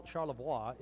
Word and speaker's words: Charlevoix: And Charlevoix is French, and Charlevoix is actually the way you pronounce it if you're French, by the way.